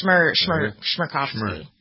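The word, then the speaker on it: Chmerkovskiy: I would pronounce that Shmerkovsky. How's that? Pretty good? – I think it's an SH sound. Shmerkovsky.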